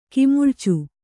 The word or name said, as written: ಕಿಮುಳ್ಚು